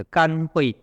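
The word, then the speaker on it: 乾慧: 所谓共地，就是甘慧地乃至佛地啊。这个字要念作甘，不能念作浅。为什么叫甘慧呢？因为它空有智慧而没有禅定水，所以是甘的啊。我们看角注 71，这共地为什么称为共地呢？共地是甘慧地等实地啊。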